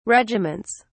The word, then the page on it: regiments: British English